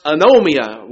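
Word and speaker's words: anomia (ἀνομία): There's a Greek word that is pronounced anomia, which means lawlessness, literally.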